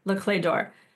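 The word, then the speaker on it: Les Clefs d'Or: And you spell it Le, like L-E-S, Clé, C-L-E-F-S, d'Or, D'OR, Le Clé d'Or.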